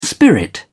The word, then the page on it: spirit: British English